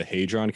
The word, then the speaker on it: Hadron: If I'm saying it right, the Hadron Collider? – Yes.